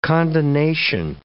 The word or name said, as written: condonation